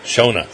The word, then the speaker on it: Shona: Now, Shauna, how do you pronounce your name? Is it Shauna or Shona? – Shona. – See, it's spelled like Shona, so I wanted to say Shona.